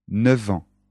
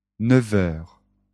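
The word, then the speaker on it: neuf: Attention à la prononciation. 9 ans. 9 heures. 9 ans. 9 heures. Avec les euros, c'est différent. 9 euros. 9 euros.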